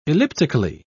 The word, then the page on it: elliptically: British English